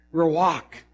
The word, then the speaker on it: ruach: And this word is, and some of you say, that's not the right pronunciation. Well, then you pronounce it. Ruach. Something like that.